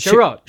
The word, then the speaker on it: Chiarot: How do you say his last name? Is it Chiarot? – Chiarot? Okay. – I mean, now they're going to pronounce it Chiarot.